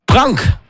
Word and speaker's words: Pronk: Hier ist Pronk, Blood Out of Stone. Das ist richtig ausgesprochen.